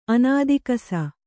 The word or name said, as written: ಅನಾದಿಕಸ